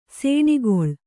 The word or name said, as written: ಸೇಣಿಗೊಳ್